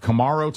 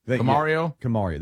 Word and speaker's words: Kamario: Is it Kamaro Taylor? Is that how you say it? Or Camaro? – Camario? Camario. There you go.